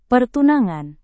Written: pertunangan

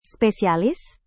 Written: spesialis

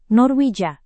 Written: norwegia

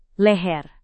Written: leher

